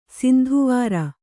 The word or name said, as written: ಸಿಂಧುವಾರ